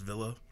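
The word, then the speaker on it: Wille: We find out that the anti-nerve is spelled Willie, but I think it's pronounced Villa.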